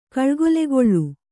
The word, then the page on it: ಕಳ್ಗೊಲೆಗೊಳ್ಳು: kannada